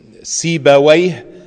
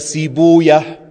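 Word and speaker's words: Sibawayh: Sagst du Sibaway oder Sibuya, Mardaway oder Marduya. Besonders wenn es Namen sind, die ein bisschen persisch sind oder also nicht arabisch sind, gibt es verschiedene Aussprachen.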